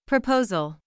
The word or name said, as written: proposal